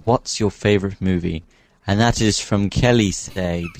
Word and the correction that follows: Kelsey: because you've spelled her name wrong. – Kelsey, jeez. – You put in an extra Y.